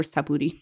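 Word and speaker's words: Xapuri: Like look S-H? At the Portuguese pronunciation has this. – Interesting. So like Chapuri? – Okay. It's like a sh sound. – Oh, okay. So Chapuri.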